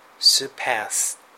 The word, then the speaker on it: surpass: 超える、 surpass。これは後ろにアクセントですね。